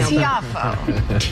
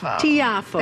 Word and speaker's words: Tiafoe: I know Tiafoe. – Tiafoe.